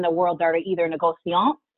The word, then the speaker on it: négociant: So you go big as a négociant. I said it right that time. – Yes, yes, you got it right, yes.